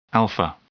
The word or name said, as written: alpha